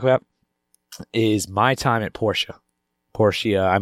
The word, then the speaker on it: Portia: It's Portia. No, you're right. – Portia? – No, Portia. You're right. You're right. I sure. feel like Portia is... – I'm pretty sure it's Portia.